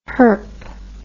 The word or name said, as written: herp